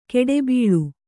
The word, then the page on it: ಗಿಡಬೀಳು: giḍa bīḷu